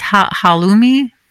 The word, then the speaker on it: halloumi: Halloumi bowl? – Yeah.